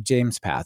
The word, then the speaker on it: JMESPath: But this one is called James Path. And I know because it says JMESPATH, pronounced James Path,